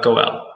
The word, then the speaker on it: Goel: Goel, G-O-E-L.